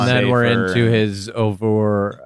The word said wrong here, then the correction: Uber: Uber. – Uber.